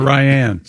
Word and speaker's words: Ryan: Say it's, that, Ryan or Ryan? – It's pronounced Ryan. People always get it wrong. Who gets the name Ryan wrong? – Ryan? – Is it R-Y-A-N? – Yeah.